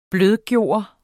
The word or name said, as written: blødgøre